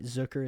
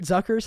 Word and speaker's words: Zucker: Or Jeremy Zucker is technically how you pronounce his last name, but people don't talk about that enough. He pronounces it that way. Everybody else says Zucker.